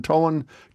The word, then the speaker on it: Tolland: Christine, you're in Tolland, Connecticut.